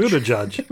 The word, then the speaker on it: Buttigieg: And he said it's just the word boot and then edge twice. Or if you prefer, you can say Buddha and then judge. – Buddha judge. – Buddha judge.